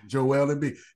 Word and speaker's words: Embiid: Yeah, that's who the Patrick Ewing of our era is, Joel Embiid. You said it right, Trey.